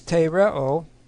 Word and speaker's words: tereo: The first one is tereo, T-E-R-E, long O.